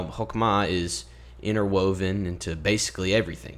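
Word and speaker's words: hokmah (חכמה): hokmah and you kind of have to like clear your throat in that second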